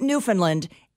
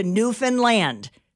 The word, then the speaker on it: Newfoundland: One of the things the locals said was that everyone pronounces it Newfoundland, and it is their biggest pet peeve. They want it to rhyme with hand. Give a hand to Newfoundland.